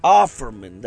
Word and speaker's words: Offerman: It's Overman. O-F-F. – Sure, Nick Oldman. – O-F-F-E-R. – Offerman. Yeah, something like that. – I guess you could pronounce it Offerman. – Offerman, that's it.